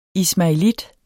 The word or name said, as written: ismaelit